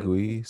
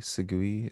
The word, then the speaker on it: Segui: Segui, Segui. It's it's French.